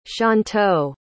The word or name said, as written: shantou